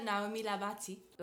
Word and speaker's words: Naomie Labaty: En Creole, Naomie Labaty.